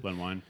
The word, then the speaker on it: Len Wein: Len Wein, is that how you pronounce it? – Len Wein.